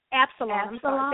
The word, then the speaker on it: Absalom: I immediately thought about David and his son, Amblesaw, and if I'm saying the other name, Absalom. – Absalom. – Thank you.